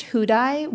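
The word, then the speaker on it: Houdaille: Called Housedale? Housedale? – It's pronounced Hudai, which Hudai. Is not how it's spelled.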